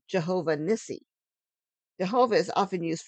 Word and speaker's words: Jehovah: Pronounced with the added vowels, it became Jehovah.